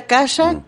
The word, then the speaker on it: akasha: bueno, este se pronuncia akasha, que viene de la lengua muerta hindú, akasha que significa éter, es energía.